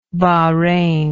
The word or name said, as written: Bahrain